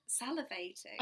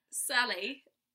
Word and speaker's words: salivating: And I'm saying salivating. Salivating. – Yeah. – Like Sally. – Yeah, like salivating.